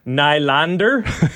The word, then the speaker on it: Nylander: I believe it's pronounced Nylander. It might actually be. – Yeah, we may have been having it wrong the whole time.